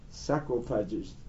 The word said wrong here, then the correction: sarcophagus: Yeah, how do you pronounce it? – Sarcophagus. – Sarcophagus. That's what it's called.